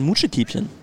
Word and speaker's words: Mutschekiebchen: Da sagt man Mutschekiebchen.